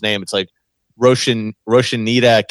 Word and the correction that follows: Rosinante: Capital. – Rosinante Capital. – Rosinante Capital.